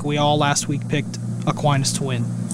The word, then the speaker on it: Aquinas: St. Thomas Aquinas, did I say that right? – Yes.